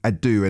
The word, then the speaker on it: Adoo: You can text as well, 85058, and you can find me as well on socials at Edward Adu, and my surname is spelled ADOO.